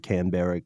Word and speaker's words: Canberra: I think that's how the Aussies pronounce it. – Like Melbourne. Exactly. Melbourne and Canberra.